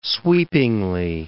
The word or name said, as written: sweepingly